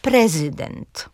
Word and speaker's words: prezydent: I ostatnie przykłady takiego akcentu na trzecią sylabę to pojedyncze rzeczowniki obcego pochodzenia. Na przykład właśnie mówimy prezydent, albo Rzeczpospolita, albo uniwersytet, czy maksimum i minimum.